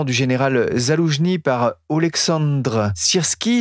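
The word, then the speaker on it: Sirski (Сирський): Je ne sais pas si je prononce bien son nom, vous le faites bien mieux que moi. Qui est ce nouveau général et que peut-il apporter ? – Alors, le général Sirski, ta prononciation, est très bonne.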